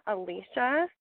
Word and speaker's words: Alisha: And then you can get a hold of me at Leah Alicia, which my name is spelled a little funky, so it's L-H-E-A. Alicia is A-L-I-S-H-A.